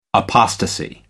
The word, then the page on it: apostasy: American English